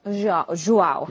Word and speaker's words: João: So it's João. – João.